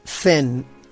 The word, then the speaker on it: Zhen: Y Zen, que se escribe Zhen, en su momento de marchitarse, es el momento de la contemplación.